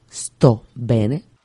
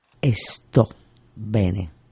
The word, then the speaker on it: sto bene: Por ejemplo, sto bene. Lo he pronunciado. Esto. Y no lo he pronunciado. Esto bene. Esto es una costumbre muy española poner la E antes de la S cuando se pronuncia también el inglés. No es Spanish, es Spanish.